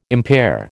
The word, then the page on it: impair: American English